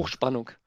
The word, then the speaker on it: Hochspannung: How do you say that again? – Hochspannung. Hochspannung. Hochspannung. Okay. Hochspannung. Yeah.